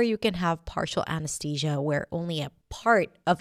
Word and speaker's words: anesthesia: Anesthesia. You might even hear people saying it with a T sound, anesthesia, but usually it's pronounced with a TH.